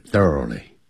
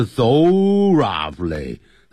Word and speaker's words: thoroughly: You should know your speech content thoroughly. 你必须要了解你的讲话的内容。 Content 是内容。 Thoroughly, 这个不要念成 thorough, 啊，不是这样念，或者 thoroughly, 要查字典，看音标 ,thoroughly, 就是 completely, C-O-M, P-L-E-T-E-L-Y